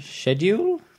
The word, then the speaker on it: schedule: S-C-H-E. – Oh, is this because if I say schedule, then I'm British. If I say schedule, then I'm American. – Okay, which is the correct British pronunciation? – Schedule? – Schedule, that's correct. – Yeah, that's the correct British pronunciation. There you go. Please give me the passport. – I say schedule. – Yeah, but the correct pronunciation is schedule.